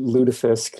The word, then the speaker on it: lutefisk: Uh, and then yeah, so why do I pronounce it lutefisk? Lutefisk is very much the upper Midwestern way of saying this.